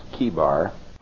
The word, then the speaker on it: Chebar: We know that the word there, C-H-E-B-A-R, is pronounced Kebar.